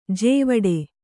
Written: ಜಾವಡಿ

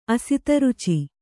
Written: ಅಸಿತರುಚಿ

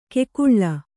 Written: ಕೆಕುಳ್ಳ

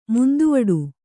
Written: ಮುಂದುವಡು

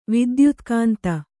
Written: ವಿದ್ಯುತ್ಕಾಂತ

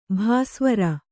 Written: ಭಾಸ್ವರ

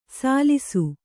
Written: ಸಲ್ಸು

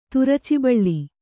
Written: ತುರಚಿಬಳ್ಳಿ

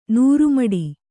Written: ನೂರುಮಡಿ

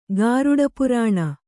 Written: ಗಾರುಡಪುರಾಣ

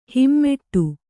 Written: ಹೆಮ್ಮಾಡಿ

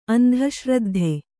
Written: ಅಂಧಶ್ರದ್ಧೆ

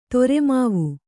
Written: ತೊರೆಮಾವು

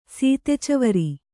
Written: ಸೀತೆಚವರಿ

